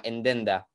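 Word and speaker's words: Nd-Nd: Sí, porque ellos, ellos se llama, él, se llama Lur y la mujer se llama NDND, el escrito. Y, entonces eh, eh, y lo, pronuncian así como Endenda.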